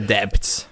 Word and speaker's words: debt: I want to repay the debts. – That's don't fair. Have to pronounce the B in debt.